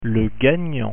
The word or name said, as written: gagnant